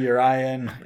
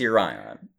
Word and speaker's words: Yorion: And a lot more Omen of the Sea love once you get Yorion into your sideboard. – I wonder how many different pronunciations we can fit into Yorion, Yorion, Urion. – I'm pretty sure it's Urion.